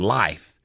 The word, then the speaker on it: Lyfe: It's called, I've been saying there, it's called Lyft. It's not, it's called Life. – Oh, Lyft. – L-Y-F-E.